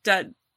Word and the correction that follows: D'Adamo: Dude, it's D apostrophe A-D-A-M-O. I want to say D'Amato. D'Adamo. That's probably right. – Whatever you say, go with it. All good. – My brain keeps wanting to say D'Amato, and I know that's not right.